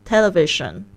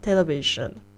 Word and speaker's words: television: Television 它那个 s i 电视那个字，它那 s i 的发音也不是发嘘的声音，是发日啊。 Television pleasure measure。